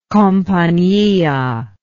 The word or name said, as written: compania